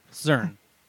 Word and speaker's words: Czern: Zern. – Zern? – Yeah. – I was going to say Schern. – Schern. – Schern, huh? – C-Z-E-R-N.